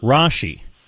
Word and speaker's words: Rasche: Rasche.